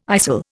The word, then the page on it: istle: Indian